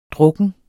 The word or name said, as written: drukken